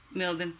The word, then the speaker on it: Mildon: Is it Milden? That she does our own, how do you say your last name? I'm sorry. – Milden.